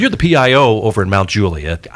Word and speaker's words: Mount Juliet: He is the public information officer for the city of Mount Juliet, an incredible city.